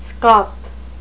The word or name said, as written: scop